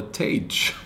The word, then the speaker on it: Tage: För att det visar sig att det uttalas, de uttalade Tage. – Ja. – Tage? Ja. – Alltså Page fast med okay. T.